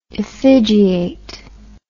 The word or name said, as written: effigiate